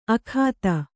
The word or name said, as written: ಆಗತೆ